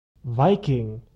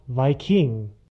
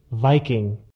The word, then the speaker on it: Viking: The little apostrophe at the beginning tells us that the accent is on the first syllable. Viking, not Viking.